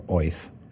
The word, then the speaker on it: Oerth: um, the world of Oerth, but we assume, or Oith, if you're pronounce it that way.